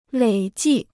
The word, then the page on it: 累计: lěi jì